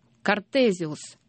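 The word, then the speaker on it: Cartesius: Картезиус. Так по-латыни произносится фамилия Декарт.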